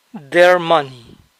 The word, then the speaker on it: their: El dinero de ellos, their money, their money. Recuerden que la I no se pronuncia, no es their, sino their.